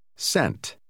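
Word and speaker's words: cent: Sent. Sent. – Steht das C vor einem hellen Vokal wie I oder E, wird es praktisch immer wie S ausgesprochen.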